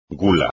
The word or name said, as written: gula